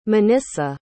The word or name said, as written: monissa